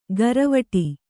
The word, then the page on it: ಘರವಟಿ: gharavaṭi